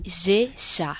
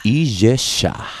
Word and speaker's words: Ijexá: ¿Cómo se pronuncia? Que me gusta mucho, ¿eh? – Ijexá. – Ijexá.